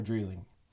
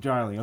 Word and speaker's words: Drieling: Is it Dryling or Dreeling? – That's correct. It's Dryling. – Dryling.